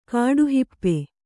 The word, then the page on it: ಕಾಡುಹಿಪ್ಪೆ: kāḍu hippe